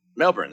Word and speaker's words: Melbourne: Melbourne. – That's, yeah. – Or Melbourne. Melbourne. Apparently they don't pronounce the R. – Okay. So no R's.